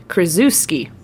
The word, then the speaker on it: Krzyzewski: And I said, Krzyzewski. Apparently, that is not how you pronounce that last name. You do not pronounce it phonetically in the English, you know, way. It is pronounced Krzyzewski.